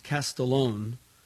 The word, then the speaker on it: Castiglione: Number two, there's a book by our guest last week, Bob Castiglione, pronounced Castellone, probably Castiglione, but, you know, Castiglione, C-A-S-T-I-G-L-I-O-N-E. It's called The Lifetime Economic Acceleration Process, The Key to Financial Success.